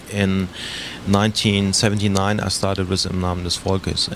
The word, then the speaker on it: Im Namen des Volkes: Well, can you pronounce it for us first? – Okay, it's called Im Namen des Volkes, okay.